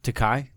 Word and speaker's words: Takei: First of all, how do you pronounce his last name? – Takei? – Takei? That's how you pronounce it? – Yeah.